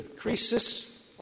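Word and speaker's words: krisis (κρίσις): The Greek word, which is pronounced either krisis, our word crisis comes from it, or krima, our word criminal comes from it, or krino, the verb to judge, always means judge.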